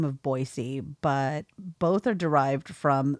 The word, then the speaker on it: Boise: Also, there is no Z in Boise. It's pronounced Boise.